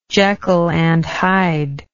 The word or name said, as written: Jekyll and Hyde